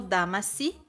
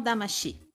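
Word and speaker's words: Damacy: Ah, é, gente está aqui para falar do disco da trilha sonora, que se chama Katamari Fortissimo Damasi, ou se a gente for usar a pronúncia japonesa, ficaria Katamari Fortissimo Damashi.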